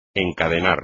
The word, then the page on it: encadenar: espagnol